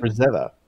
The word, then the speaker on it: Frazetta: Frazetta.